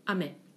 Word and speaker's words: 雨: Please tell me, what's the pronunciation of rain? – Ame. – Ame. And candy? – Ame.